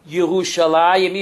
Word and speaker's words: Yerushalayim: And therefore, we pronounce the word Yerushalayim, even though it's spelled Jerusalem.